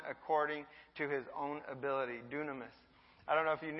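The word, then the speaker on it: dunamis (δύναμις): This word power is pronounced dunamis in the Greek, and it means power or deed of power.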